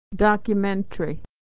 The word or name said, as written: documentary